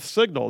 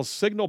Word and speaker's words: Cygnal: with Signal. That's spelled C-Y-G-N-A-L, Signal Polling and Analytics.